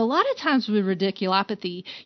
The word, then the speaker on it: radiculopathy: And you have to tell us what ridiculopathy is, and even if I pronounced it correctly. Yes. – You did. You did.